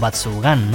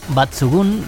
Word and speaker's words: Batsugun: Efectivamente, y por eso muchas veces hemos acabado titulando o pronunciando este título como Batsugan, ¿no? Porque bueno, ya que hay pistolas, hay armas, hay naves, pero no, Batsugun viene literalmente de dos palabras japonesas que se juntan,